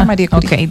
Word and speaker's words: Marie Curie: Mira, cerramos con una frase de empoderamiento de Marie Curie. ¿Es que se pronuncia? – Ajá, Marie Curie.